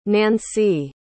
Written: nainsi

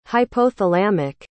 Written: hypothalamic

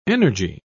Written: energy

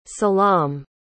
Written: selam

